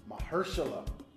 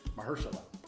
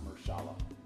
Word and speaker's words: Mahershala: Mahershala is how you say his name. From what I remember. Mahershala Ali, not Mahershala.